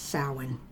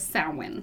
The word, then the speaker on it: Samhain: It is pronounced Samhain. – Yes, so it's spelled funny, but it is Samhain.